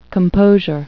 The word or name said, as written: composure